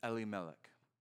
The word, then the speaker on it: Elimelech: His name is Elimelech. Now, I say it that way because that's the Hebrew pronunciation, but it's important.